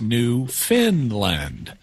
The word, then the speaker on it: Newfoundland: It's spelled Newfoundland, but it's pronounced Newfoundland, even though it's not located in Scandinavia.